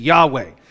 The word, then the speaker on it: Yahweh: We often pronounce it Yahweh, which even that pronunciation is something of an approximation. The pronunciation Yahweh is our best guess. I had a Hebrew professor at RTS who was very insistent that it's Yahweh. And he said, that's how Moses pronounced it.